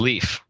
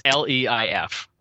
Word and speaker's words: Leif: My name is Leif. Most people, it's Leif, right? But people could either spell it grammatically correct or as leaf in a tree or get it totally wrong and put an S in there.